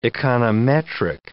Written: econometric

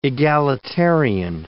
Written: egalitarian

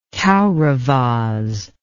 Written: kauravas

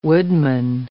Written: woodman

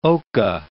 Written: oca

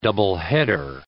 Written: doubleheader

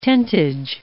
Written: tentage

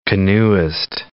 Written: canoeist

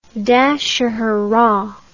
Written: Dashahara

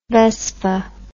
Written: vespa

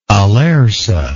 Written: alerce

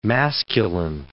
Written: masculine

